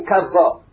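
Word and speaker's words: kav (קו): The word that we, yes, it's written kavva, which means its circumference. But we have a tradition to pronounce it kav, meaning circumference.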